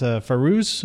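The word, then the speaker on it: Fairuz: I have it down as Farouz. Is that right? That's how you pronounce her name? – Yes.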